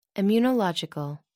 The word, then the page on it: immunological: American English